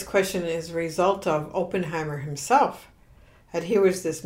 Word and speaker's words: Oppenheimer: Robert Oppenheimer.